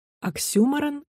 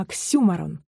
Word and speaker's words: оксюморон: Но если глупость произносится намеренно, человеком ярким, талантливым и остроумным, то, скорее всего, это не глупость, а оксюморон. Оксюморон, на первый взгляд, кажется словом французским, и есть огромное желание сделать ударение на его последнем слоге. Не надо. Это неправильно, потому что слово это греческого происхождения.